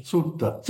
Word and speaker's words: Suetta: His name is S-U-E-T-T-A. Suta? Is that how you pronounce it? S-U-E-T-T-A. – Sutta. – Sutta. See, he had it better than I did.